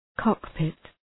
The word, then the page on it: cockpit: ‘kɒk,pıt